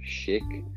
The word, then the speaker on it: chic: When you're trying to be chic. – Chic, yeah. That's yes. There. You and your pronunciations. – Well, it's French, so blame them.